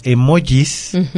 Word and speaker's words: emojis: Y los emojis, así se pronuncia aunque se escribe con J, son los que se utilizan como caritas.